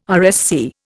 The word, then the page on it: RSC: Indian